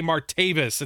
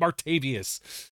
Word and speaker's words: Martavis: And then I go, oh, that's how you say Martavis. It's not Martavius.